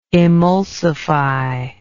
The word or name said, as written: emulsible